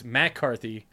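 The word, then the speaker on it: MacCarthy: So you got the Liam McCarthy. Actually, it's Matt Carthy.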